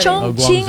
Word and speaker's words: Chongqing: Ang, everybody thinks it's Chongqing, but it's a Chinese, the Q is pronounced as CH din.